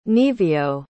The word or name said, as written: Nevio